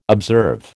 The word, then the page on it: observe: American English